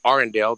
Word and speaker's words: Arundel: I don't even know if I'm going to say this right, but in Anne Arundel County. – Arundel, that's correct.